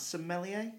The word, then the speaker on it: sommelier: How do you pronounce sommelier? – Sommelier.